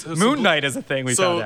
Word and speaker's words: Knight: Yes, Moon Knight is a superhero. – Knight with a K. – Yeah, Knight with a K. – Is silent. – Moon Knight.